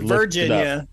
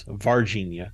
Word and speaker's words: Varginha: Not Virginia. That's in America. Virginia. – No, it's Varginha. And I actually looked up, and there is some correlation, I think, between Virginia in the U.S. and Varginha in the Brazil.